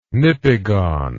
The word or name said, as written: nipigon